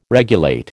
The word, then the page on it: regulate: American English